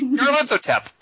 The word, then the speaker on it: Nyarlathotep: Persona, yeah, the first Persona had Nyarlo Hotep, or how do you say that? – What? You're thinking of Bubba Hotep, that movie about the mummy. – Whatever. Anyway. – Oh, no, no, you're right. Nyarlathotep. Oh, yeah, yeah. Nyarlathotep.